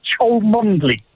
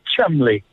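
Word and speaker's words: Cholmondeley: Instinctively, you'd think Cholmondley. – Jeez, you've stumped me with that one. – Well, okay. In fact, the correct pronunciation is, guess what? It's Chumley.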